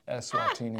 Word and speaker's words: Eswatini: It's actually pronounced Eswatini. – I said it wrong.